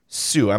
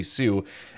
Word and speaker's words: Hsu: He tweeted, Patrick Kane's representative, Pat Person, says Kane had a hip resurfacing today under Dr. Edwin Sue. I'm assuming the H is silent. HSU would be Sue.